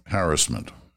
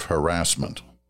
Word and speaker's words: harassment: And people still write to me because they don't like it when I say harassment instead of harassment. Well, if you look in a dictionary, you'll see both are acceptable pronunciations of the word.